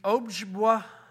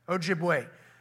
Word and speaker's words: Ojibwe: It depicts the Ojibwa, O-J-B-W-A, war party. How do you say that? Ojibwa? Ojibwe. An Ojibwe war party, which I believe was part of the Sioux Nation.